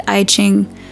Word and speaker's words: I Ching: In translation, it's, well, you pronounce it I Ching in English, and its translation is Book of Changes, a guide to life's turning points.